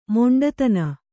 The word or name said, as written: ಮೊಂಡುತನ